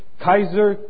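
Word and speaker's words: Kaiser (Καῖσαρ): Rehusaban pronunciar las palabras Kaiser Curios. Kaiser como el hospital.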